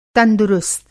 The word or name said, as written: तंदुरुस्त